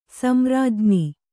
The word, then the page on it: ಸಂರಾಜ್ಞಿ: Kannada